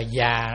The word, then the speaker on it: già: Chữ vô giá đây không phải là gọi là quý báu, không có gì giá trị hơn nữa, mà chữ giá đây cũng đọc là già đó. Giá hay già đó, tức là ngăn.